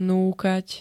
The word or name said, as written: Núkať